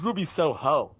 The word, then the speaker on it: Ruby Soho: So people calling her Ruby, they're putting the wrong emphasis on the wrong syllable. Kills me. She's Ruby Soho.